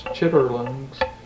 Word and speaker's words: chitterlings: I've heard of chitlins, yes, but I think it's pronounced chitterlings.